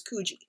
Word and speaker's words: Coogee: And I looked it up and that's how they pronounce it is Coogee.